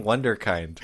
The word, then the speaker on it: Wunderkind: I'm like, why am I saying Wunderkind? – Because he said Wonder Kid and they're like, you know it's not Wonder Kid, right?